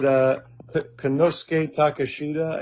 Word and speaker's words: Konosuke Takeshita: Is it Konosuke Takeshita, I think is how it's pronounced? – Yes, yes.